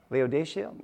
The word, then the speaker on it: Laodicea: Verse 14, and to the angel of the church of the Laodiceans write. So, Laodicea? Am I saying that wrong? Laodicea, thank you.